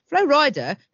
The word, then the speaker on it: Flo Rida: Flo Rida, whose stage name honours his home state but is pronounced like Flo Rider, is fastidious about his physique.